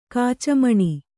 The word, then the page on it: ಕೆಚ್ಚುಮನ: keccumana